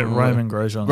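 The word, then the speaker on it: Romain Grosjean: Was it Roman Grosjean? – Yeah, Roman Grosjean.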